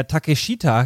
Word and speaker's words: Takeshita: Und der Eddie, wie er sich etwas grobmotorisch bewegt, schafft es noch, seine Spinning Backfist rein anzubringen und trifft da den Takeshita, der ja Takeshita geschrieben wird, aber Takeshita ausgesprochen, liebe Hörende und auch lieber Stefan, der ja auch irgendwann sein.